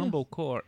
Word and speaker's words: Mumblecore: Ela começou a carreira dela no Mumble, Mumblecore, chama é assim? Como é que pronuncia? Mumblecore, isso.